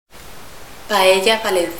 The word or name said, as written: paella